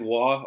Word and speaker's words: Roy: Like, Patrick Waugh, or whatever we were talking about before, it's spelled Roy.